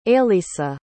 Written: ailisa

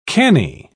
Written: canny

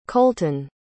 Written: colton

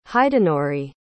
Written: hidenori

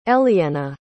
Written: elliena

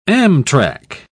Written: amtrac

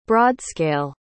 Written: broadscale

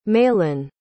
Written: mahlon

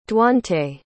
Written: duante

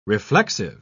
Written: reflexive

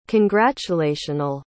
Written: congratulational

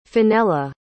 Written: finella

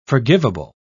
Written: forgivable

